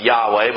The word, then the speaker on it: Yahweh: We pronounce it Yahweh, but we know the consonants were a Y, a W, and an H. But we don't know what filled in, because they left the vowels out because they couldn't write it for the sacredness of it.